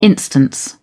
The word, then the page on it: instance: British English